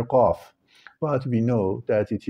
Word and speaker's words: Qaf: Actually, letter C in Arabic is pronounced Qe, therefore the full name of this letter in Arabic is Qaf.